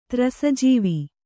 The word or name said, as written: ತ್ರಸಜೀವ